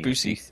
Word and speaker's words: Buscemi: Buscemi, isn't it? – Yeah. – God, how do you say his name? – Gary Buscemi. – Buscemi.